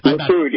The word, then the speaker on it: Likud: The Likud, which is his party. – Likud, yep.